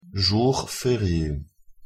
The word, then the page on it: jour ferie: French